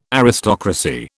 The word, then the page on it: Aristocracy: British English